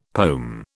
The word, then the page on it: pome: British English